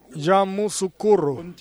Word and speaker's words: Yamoussoukro: Yamoussoukro.